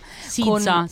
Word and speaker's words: SZA: Sizza si pronuncia, Sizza, l'ho scoperto Sizza.